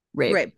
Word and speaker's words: Rabe: Starring Elizabeth Olsen, has, is it, how do you say her last name? Lily Rabe? – Or yeah. – Rabe. Okay. Yeah, so Lily Rabe.